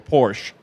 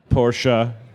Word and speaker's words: Porsche: He loved the design so much that he took the 2011 livery from a Porsche, all right, that was down... – Say it right, Porsche. – Porsche. – Thank you.